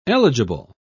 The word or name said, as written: eligible